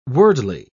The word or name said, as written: worldly